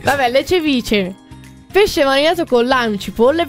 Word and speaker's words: ceviche: C'è la H. Però in spagnolo si legge cevice. La H in spagnolo rende la...